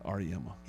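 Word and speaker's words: Auriemma: Help me with this UConn coach, Gene. – Yeah, Gino. – Ariema? – R.E.M.? Yeah, R.E.M.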